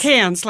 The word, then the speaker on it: Cairns: That's C A I R N S, which we pronounce Cairns, but nobody in Australia says it that way. – They nobody. say Cairns. – Okay, nobody. Cairns, nobody. Cairns like C A N S. Cairns. Cairns.